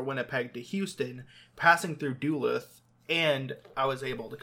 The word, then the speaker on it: Duluth: Did I pronounce that right? – Duluth, yeah. – Yeah, Duluth.